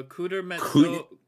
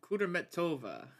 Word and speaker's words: Kudermetova: Uh, Kudermetso- Kudermetova.